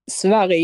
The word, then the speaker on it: Sverige: How do you say Sweden if you're Swedish? – Sverige, yes.